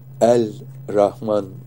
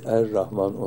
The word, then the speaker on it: Er-Rahman: Bilirsiniz, tamlama olduğu zaman lamlar ra olur. El-Rahman kelimesi Er-Rahman olarak okunur.